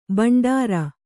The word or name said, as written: ಭಂಡಾರಿ